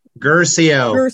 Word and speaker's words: Guercio: Guercio.